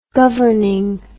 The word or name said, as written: governing